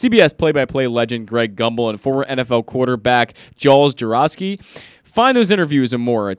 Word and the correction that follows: Jaworski: Jaworski. – Did I say it wrong? That's all right. – You said Jaroszky.